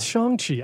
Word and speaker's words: Shang-Chi: Apparently, yeah. It's Shang-Chi. I felt like a fake fan. I've been saying it wrong my entire life.